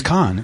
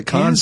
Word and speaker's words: Cannes: Am I saying that right? – I think it's Cannes. – Okay. Is it Cannes? C-A-N? – I've heard it both ways.